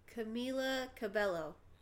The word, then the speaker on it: Camila Cabello: Is that how you say it, or is it Camila Cabello? Cabello. – Camila Cabello.